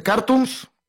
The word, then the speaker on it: Cartoons: Sí, bueno, las redes sociales de Cartoons, con acento en la U, Ok.